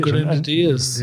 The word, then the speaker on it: Grenadiers: Grenadiers, and, and, yeah, yeah, yeah, yeah, You did well to pronounce, pronounce that.